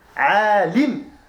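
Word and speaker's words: عَالِم: Et celui qui va méditer sur ce monde, Al-Alam, avec un fatha sur le lam, un fatha sur le lam, Alam, le monde, Alim, ça s'écrit de la même manière avec la haraka qui change. Une kasra sur le lam, c'est un savant.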